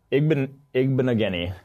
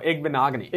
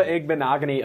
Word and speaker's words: Igbinoghene: And then number 10, Noah Igbinoghene. – No, Igbinoghene. – Igbinoghene, God. – Noah Igbinoghene of Auburn. I looked it up right before, but I feel good about that pronunciation.